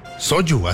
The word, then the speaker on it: soju: Soju, ¿así se pronuncia? – Así se pronuncia, soju. – ¿Se escribe soju? – Sí, soju. – Shayo. Shayo. – Sí, porque es de origen coreano.